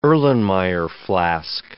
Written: Erlenmeyer flask

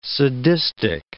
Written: sadistic

